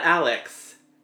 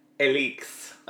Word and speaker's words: Alix: Not Alex. Alix. – Oh, yeah. Well, it's spelled A-L-I-X-E.